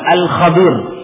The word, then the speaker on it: Khadir: Ada 2 pengucapan dari nama Khadir ini. Yang bersama Nabi Musa alaihissalam, ya, disebut Khadir dan juga disebut dengan alif lam al Khadir. Dua-duanya boleh.